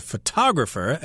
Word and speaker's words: photographer: And a person who does this is a photographer. Again, that would be the accent on the second syllable as well.